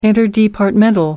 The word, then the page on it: interdepartmental: American English